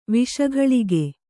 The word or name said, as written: ವಿಷಗಳಿಗೆ